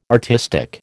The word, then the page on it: artistic: American English